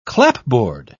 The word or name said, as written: clapboard